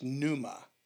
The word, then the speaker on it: pneuma: Well, your spirit is something that in the Greek word is translated as it's pneuma, and it's spelled with a P. – Mm-hmm. – So pneuma, like pneumonia. If you ever wondered why pneumonia is spelled with a P. – Yeah, that always threw me off. – And you don't pronounce it.